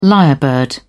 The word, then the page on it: lyrebird: British English